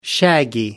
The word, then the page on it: shaggy: American English